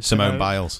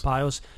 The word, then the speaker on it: Simone Biles: That Olympian, is it, how do you say her name? – Simone Biles. – Biles.